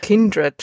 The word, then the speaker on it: Kindred: Kindred spricht man das. – Kindred. Echt? Mhm. Ist mein Leben lang falsch ausgesprochen.